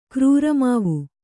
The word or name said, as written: ಕ್ರೂರಮಾವು